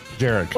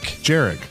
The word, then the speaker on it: Jarek: Jarek. – Oh, you know what? I like that pronunciation. – Is it Jarek or Eric? – Jarek. – It's Jarek with a J.